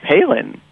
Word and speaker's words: Palin: Isn't it Palin? – Education. Is it Payon? Did you say Payon or Palin? – No, I said Palin.